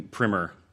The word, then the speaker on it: primer: It's sort of a it's a Dick and Jane primer. or Primer, however you want. Mm. You know, they say Primer in Contact.